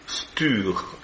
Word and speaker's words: Stur: Le mot Saturne en chaldéen se prononce Stur, S-T-U-R.